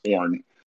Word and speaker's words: Orn: It's spelled Orin, but it's just pronounced Orn.